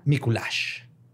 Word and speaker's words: Miculax: Hoy les voy a contar la historia de José María Miculash. – Miculash. – Miculax. Se escribe Miculax. Miculax. – L-A-X. Miculax. Miculax. perro. – Pero se pronuncia Miculash.